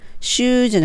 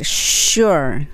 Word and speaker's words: sure: で、この発音はス、スって音なので、 sure です。シューじゃなくて、 sure、 sure。